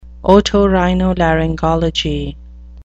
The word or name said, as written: otorhinolaryngology